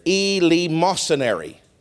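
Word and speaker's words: eleemosynary: But they were so poor, they would go to a certain area, and it was called the East, how did I say it? It's actually a place. They would call it the Elemosynary. And after the service, they would go to this place called the Elemosynary.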